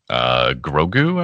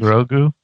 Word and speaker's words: Grogu: Grogu, I believe. – Grogu.